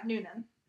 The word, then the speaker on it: Noonan: Yeah, Noonan.